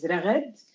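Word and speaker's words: Raghad (رغد): So my actual name in Arabic is Raghad. Mm-hmm. It's always been a very hard name to pronounce, even for the people who speak Arabic, actually.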